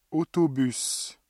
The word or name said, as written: autobus